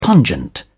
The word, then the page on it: pungent: British English